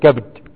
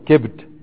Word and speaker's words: كبد: فائدة الكبد بفتح الكاف وكسر الباء أدي لغة ويجوز تسكينها مع إبقاء فتح الكاف كبد وكسرها أيضا كبد تمام.